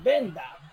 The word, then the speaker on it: Bender: Y en, y Bender, por ejemplo, es venda ¿Está bien o no? – Sí, está bien, absolutamente.